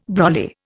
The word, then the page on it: brolly: Indian